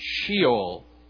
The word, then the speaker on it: Sheol: Now, the Hebrew is Sheol. You may have heard it pronounced in different ways, but usually in English it's pronounced Sheol.